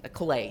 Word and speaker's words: Calais: Calais? – Calais is how it's normally pronounced, but 20 points.